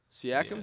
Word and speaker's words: Siakam: That Pascal Siakam guy, Siakam. – Yes. Oh yeah. 40, I think you're saying it right. – Maybe. I'm saying it right. – Yeah, at first you were saying it wrong.